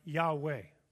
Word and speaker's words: Yahweh: It is pronounced in Hebrew something like Yahweh.